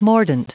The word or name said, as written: mordant